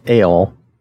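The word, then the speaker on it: El (אֵל): It is pronounced El. And you notice how they spelled that like El, A-L-E. So it's pronounced like El, even like the letter El, but it's spelled E-L for our purposes, okay?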